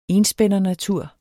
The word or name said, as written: enspændernatur